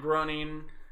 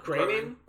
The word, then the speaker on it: Groening: I didn't realize This Eureka's trailer, we finally got the new trailer for the Matt Groening. That's how you say his name. Groening? – I always call him Groening.